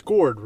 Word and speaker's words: Gourde: Is it Yanni Gourde? It's Gourde, right? That's how you say the Tampa Bay. – Yeah, correct. No, you didn't say it wrong.